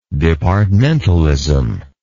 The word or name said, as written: departmentalism